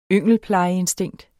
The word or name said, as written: yngelplejeinstinkt